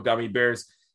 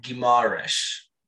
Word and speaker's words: Guimarães: I've been calling him Bruno Gummy Bears. Bruno Guimarães. – I think it's closer to Bruno Guimarães. – Yes. – That's wrong as well, but it's closer than gummy bears, if nothing else.